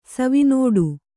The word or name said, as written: ಸವಿನುಡಿ